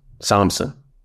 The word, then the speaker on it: Samsun: Samsun, that's how you pronounce it.